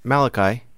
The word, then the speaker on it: Malachi: Is that how you say it? Malachi? – Malachi, sure.